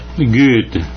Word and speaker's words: Goethe: Goethe. – Ya, yeah, bien. – Goethe, pero siempre lo hemos dicho, así. No tienes que decirnos bien. – Goethe.